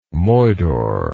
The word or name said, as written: moidore